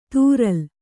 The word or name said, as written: ತೂರಲ್